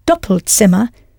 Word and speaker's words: Doppelzimmer: You had to change the vowel sound slightly, double to doppel, and I hope you pronounced the Z as though it was a TS, Doppelzimmer.